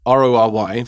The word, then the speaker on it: Rory: Well, it's of course it's well R O R Y for the Rory, but uh, Mackay is spelled M A C K A Y.